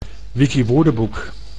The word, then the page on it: Wikiwoordenboek: Dutch